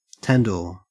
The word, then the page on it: tandoor: Australian English